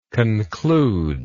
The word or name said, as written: concluding